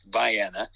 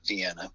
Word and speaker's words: Vienna: One here in Northeast Ohio is, and it's pronounced Vienna. It's not Vienna.